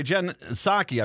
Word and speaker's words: Psaki: I saw Press Secretary Jen Psaki, I believe is how you say it, P-S-A-K-I, Jen Psaki.